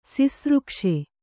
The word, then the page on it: ಸಿಸೃಕ್ಷೆ: Kannada